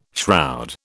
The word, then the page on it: shroud: British English